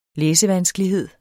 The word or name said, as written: læsevanskelighed